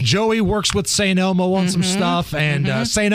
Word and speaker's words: St. Elmo: Thank you for saying it right, because I don't want the mean tweets to come in. I can't believe they put an S at the end of it.